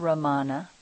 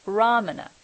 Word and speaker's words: Ramana: If for those of you who don't know, it's not Ramana, which sounded like Ra-ma-na. It's Ram-ana, Ramana.